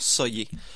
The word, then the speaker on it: Sayeh: Juste pour préciser, ça s'écrit S-A-Y-E-H, ça se prononce Soyer.